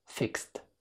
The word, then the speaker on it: fixed: الكلمة اللي فوق دي الماضي بتاع fix نطقها كده fixed. Fixed. سمع؟ مفيش أي كسرة بين صوت الـ X وصوت الـ the.